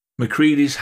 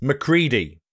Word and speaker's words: MacReady: it's spelt MacReady, but it's MacReady.